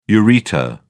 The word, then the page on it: ureter: British English